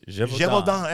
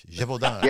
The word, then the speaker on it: Gévaudan: The Beast of Gévaudan. – That sounds French. Gévaudan.